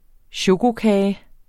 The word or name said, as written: chokokage